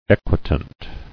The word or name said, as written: Equitant